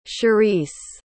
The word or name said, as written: sherise